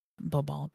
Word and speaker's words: Bourbon: Every is then going to make a very short stop over at the French Isle of Bourbon, as I like to say it. It's spelled Bourbon, but Bourbon.